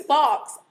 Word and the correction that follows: faux: F-A-U-X, wrong from last week, Pat Vargas. – Oh, foe.